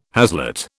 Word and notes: haslet: British English

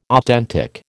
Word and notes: authentic: American English

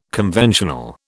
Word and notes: conventional: British English

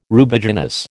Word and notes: Rubiginous: American English

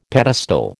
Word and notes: pedestal: American English

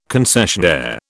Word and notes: concessionaire: British English

